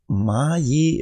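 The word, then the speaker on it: Maije: Maje im Saarland 2017.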